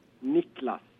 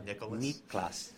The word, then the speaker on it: Niklas: Niklas. – Yeah. Not Nicholas. – Not Nicholas. – Because that's how we pronounce it in English.